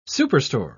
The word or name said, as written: superstore